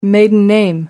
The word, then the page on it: maiden name: American English